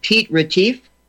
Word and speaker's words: Piet Retief: His granddaughter was there at the laying of the cornerstone of the Voortrekker Monument, along with the great-granddaughter, and help me with the pronunciation, of Piet Retief. – Yes, that's perfectly correct pronunciation.